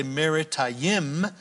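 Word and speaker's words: Merathaim: How do you say Marathaim in Hebrew? You say Marathaim, and it means in Hebrew double rebellion.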